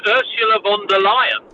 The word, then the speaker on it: Ursula von der Leyen: Okay, well, first of all, just on the pronunciation, it's Ursula von der Leyen.